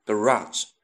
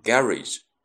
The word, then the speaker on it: garage: 在美国呢，我们通常读作 garage，那么在英国呢，读成 garage。